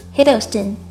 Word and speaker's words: Hiddleston: 梅梅 Taylor Swift 不要受中文翻译的影响把 Taylor 的 first name 念成了 Tyler 正确的读法应该是 Taylor Taylor Swift 抖森 Tom Hiddleston 抖森的姓正确读法是 Hiddleston 轻浊音 T 要浊化成 T